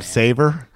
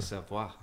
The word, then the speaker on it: Savoie: You mean it's not Savor? – I'm like, "Is it Savoy, Savoie?" He's like, "A Savoie."